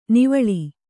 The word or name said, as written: ನಿವ್ವಳ